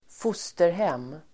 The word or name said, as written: fosterhem